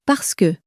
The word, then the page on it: parce que: french